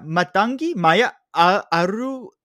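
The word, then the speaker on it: Mathangi Maya Arulpragasam: Matangi Maya Aruprasam. – Are you sure you're not Indian, man? You're pronouncing that like pretty solidly.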